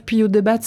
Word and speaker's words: Puyodebat: Puyot de Batte à Cap-Breton. Bonjour Laurent. – Bonjour. – Je suis pas loin c'est bien. – Puyot de batte.